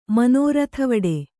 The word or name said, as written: ಮನೋರಥವಡೆ